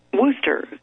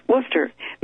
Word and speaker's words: Worcester: Worcester, I didn't pronounce it right, did I? – Worcester, Massachusetts.